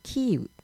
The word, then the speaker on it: キーウ: まあ、キエフは、まあ、ロシア語由来で、まあ、ウクライナ語ではキーウなので、キーウの大門とも言うらしいです。